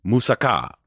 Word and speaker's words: moussaka: Chicken riganati, original pork souvlaki, Greek potatoes, pastitsio, or dolmades, or moussaka. Really trying hard to accent that last syllable on that.